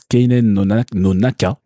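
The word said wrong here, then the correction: Kenen no Naka (犬猿の仲): Je l'ai raté. Attends, Kenen no Naka.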